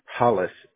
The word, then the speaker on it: Hollis: Hollis. H-O-L-L-I-S.